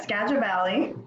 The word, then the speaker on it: Skagit Valley: Skagit Valley? – Yeah, Skagit Valley.